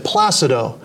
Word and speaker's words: Placido: and I asked him one time how to pronounce his name, and he said Placido.